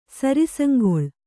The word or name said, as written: ಸರಿಸಂಗೊಳ್